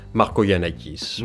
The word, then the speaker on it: Markogiannakis: Christos euh, Markouniakis, je le prononce bien. – Marco Yanakis. – Marco oui. Oui. Ouais, parce qu'en en fait, euh, il y, a des, y, y a des ou, il y a des G, mais en fait, on les prononce pas.